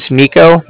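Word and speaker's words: Niko: Okay, my name's Nico, that's N-I-K-O, and I'm calling from the Bank of Cardiff. That's spelled C-A-R-D-I-F-F, as in Frank.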